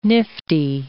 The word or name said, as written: nifty